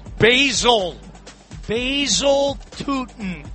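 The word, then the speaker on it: Travis Etienne: It was a 1-yard touchdown run by Vishal Tootin. Did I say it right? – Basil Tootin, I believe, is the correct pronunciation.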